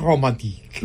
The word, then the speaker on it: Romantique: John Elliott Gardner conducting the Orchestre Révolutionnaire et Romand. Did I say that right? – Et romantique.